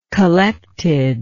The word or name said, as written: collectedness